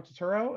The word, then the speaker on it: Turturro: Um, I also really love um, you know, John Turturro.